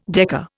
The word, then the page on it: decker: Indian